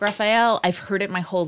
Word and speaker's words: Raphael: It's June, Diane, Raphael. I said it correctly. I've been saying Raphael incorrectly. – Okay. – And then Casey corrected me.